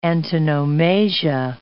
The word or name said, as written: antonomasia